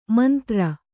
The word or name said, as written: ಮಂದುರೆ